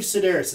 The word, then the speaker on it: Sidaris: And Andy, I believe Sidaris is how it's said. Mm-hmm. I think that's how I've heard it being said before.